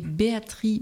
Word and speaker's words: Béatrix: et Béatrice Beck, alors ça s'écrit Béatrix, mais on dit pas le X.